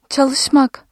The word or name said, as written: çalış-